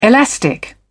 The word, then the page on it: elastic: British English